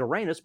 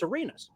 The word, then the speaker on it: Arenas: I pronounced his name Arenas. And after about two or three games of that, I think Gilbert's dad reached out to Brian Jeffries and said, if it's cool, if you could tell that one radio guy that the pronunciation isn't Arenas, but it's Arenas.